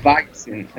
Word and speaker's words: Weizen: creo, que, creo que se pronuncia Weizen o algo así, no sé. – Pero bueno. Weizen, claro, porque es alemana.